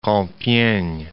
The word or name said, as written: Compiegne